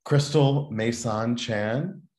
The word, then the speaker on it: Crystal Meisaan Chan: Crystal Mason Chan.